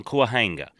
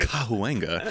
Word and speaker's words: Cahuenga: It shouldn't be in an apartment on Cahuenga. – Yeah. – Ooh. Respect to the pronunciation. – Thank you. – Cahuenga.